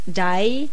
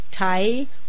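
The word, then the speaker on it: dai (大): It can be pronounced dai, tai, or o are the most common pronunciations.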